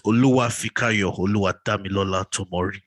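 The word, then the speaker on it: Oluwafikayo Oluwadamilola Tomori: No, you didn't say it right. Oluwa Fikayo, Oluwa Damilola Tomori.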